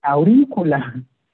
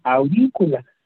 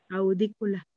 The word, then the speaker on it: aurícula: Tiene acento, es ¿eh? aurícula. aurícula. Tiene acento en la I, aurícula. – Audícula.